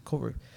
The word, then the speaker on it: Kauru: And he, it's spelled K-A-U-R-U, Corey.